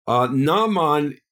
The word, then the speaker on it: Naaman: Naaman is spelled with an ayin, the Hebrew letter ayin, which is silent, but spelled with an aleph, which is also silent, so therefore pronounced the same way.